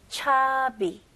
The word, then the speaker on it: chubby: 발음은 추 아니에요. chubby. 그래서 B가 두개 있는 거예요. 오케이? 그거 헷갈리지 않기 위해서 B가 하나였으면 추비나? 근데 B가 두 개니까 chubby.